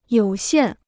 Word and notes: 有限: yǒu xiàn

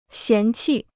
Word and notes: 嫌弃: xián qì

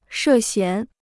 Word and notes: 涉嫌: shè xián

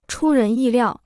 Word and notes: 出人意料: chū rén yì liào

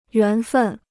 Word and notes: 缘分: yuán fèn

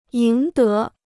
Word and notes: 赢得: yíng dé

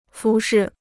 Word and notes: 服饰: fú shì

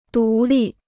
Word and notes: 独立: dú lì

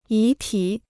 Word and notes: 遗体: yí tǐ

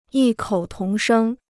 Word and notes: 异口同声: yì kǒu tóng shēng

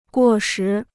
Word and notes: 过时: guò shí

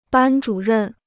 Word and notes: 班主任: bān zhǔ rèn

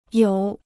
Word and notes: 有: yǒu

